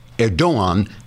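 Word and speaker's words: Erdoğan: pronounced Erdogan because the G is silent, as in angster.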